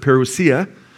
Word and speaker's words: parousia: He says concerning Christ's coming, and that the Greek word there is parousia. Some pronounce it parousia, okay?